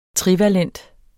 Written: trivalent

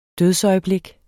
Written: dødsøjeblik

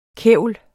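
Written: KÆVLETS

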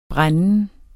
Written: brænden

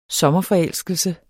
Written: sommerforelskelse

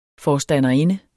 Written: forstanderinde

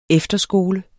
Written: efterskole

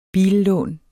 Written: billån

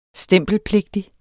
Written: stempelpligtig

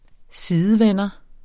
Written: sidevender

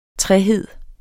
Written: trehed

